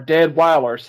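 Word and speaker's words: Deadwyler: And it's got Danielle Deadwater in it, or Deadweiler, sorry, Deadweiler, I don't know why I said Deadwater, I mispronounced, my bad.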